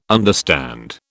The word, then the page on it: understand: British English